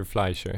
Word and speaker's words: Fleischer: Nej men eh, för det var ju Peter, eller Peter Fleischer heter han väl, det är väl med mm. amerikanskt uttal och inte mm. svenskt nödvändigtvis.